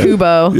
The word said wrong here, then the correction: Koba: Yeah, whatever. – Eruption. – Koba. – There it is.